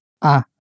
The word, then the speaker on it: ha: You have in Spanish is a. – A. – This is spelt H-A, but the H is always silent in Spanish, so you just hear the a sound.